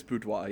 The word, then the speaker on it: boudoir: Oh, it is Boudoir, yeah. – Yeah, so you gotta French it out.